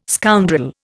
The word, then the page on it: scoundrel: Indian